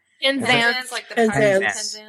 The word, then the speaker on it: Penzance: Penzance.